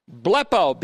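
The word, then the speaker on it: Blepo (βλέπω): Blepo, B-L-E-P, long O, can have the sense of being awakened or enabled to see.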